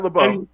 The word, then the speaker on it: LaBeouf: Just reading the first paragraph here, Monday Variety reported that Gibson is set to co-star with Shia LaBeouf, or Boof, whatever you say his name is. Yes, Shia LaBeouf.